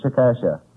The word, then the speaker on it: Chickasha: C-H-I-C-K-A-S-H-A. I said Chickasha. – Oh, Mr. McNeil. – That is not right, I've no, no. No. But the name is pronounced Chickasha.